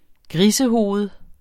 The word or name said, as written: grisehoved